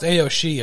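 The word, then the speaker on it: Aos Sídhe: Pronounced Aoshi, it's spelled Aoisidhe, and that's Aoshi.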